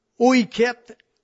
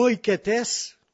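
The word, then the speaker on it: oiketes (οἰκέτης): Un autre point, c'est le mot « oiket » ou « oiketes », ils doivent prononcer le « tes » à la fin, qui a été traduit encore par serviteur.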